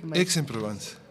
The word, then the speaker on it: Aix-en-Provence: Aix-en-Provence. – Aix-en-Provence, Aix-en-Provence de la Ópera de París.